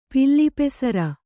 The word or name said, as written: ಪಿಲ್ಲಿಪೆಸರ